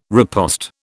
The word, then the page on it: riposte: British English